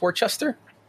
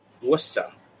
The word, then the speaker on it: Worcester: But what's how do you pronounce Worcester? – Worcester. That's how you say it with a Boston accent. If you wanted to say it in its proper English accent, you'd say Worcester.